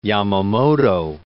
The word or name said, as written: Yamamoto